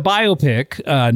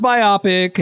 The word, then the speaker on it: biopic: This is it's a biopic, not pronounced biopic, everyone. Biographical picture.